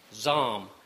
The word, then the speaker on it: Salm: It's spelled S-A-L-M, pronounced Zalm, with a Z, or as in you would say in the U.S., a Z.